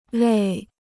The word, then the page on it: 累: léi